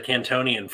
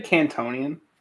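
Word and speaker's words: Kantonian: It's like in the Pokemon games where you somehow get access to the Cantonian forms too. – Yeah. – So that's cool. Did you say Cantonian? – That's how they say it.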